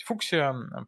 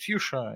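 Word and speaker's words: fuchsia: По-русски можно говорить фуксия, по-английски можно говорить фьюша и или фукша, и будет нормально.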